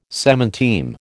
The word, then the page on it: semanteme: American English